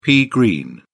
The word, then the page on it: pea green: British English